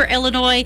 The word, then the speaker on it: Illinois: Remember, Illinois, there's an S on the end, but that S is silent.